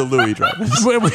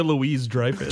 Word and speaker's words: Louis-Dreyfus: She doesn't pronounce the S. – Okay. – Julia Louis-Dreyfus.